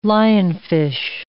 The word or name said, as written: lionfish